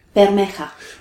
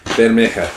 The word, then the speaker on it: Bermeja: Bermeja. – Bermeja, yes.